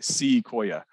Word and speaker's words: Ssiquoya: And of course, Sequoia spelled his name S-S-I-Q-U-O-Y-A. So it's S-S-I-Q-U-O-Y-A, Sequoia. It's four syllables instead of three syllables.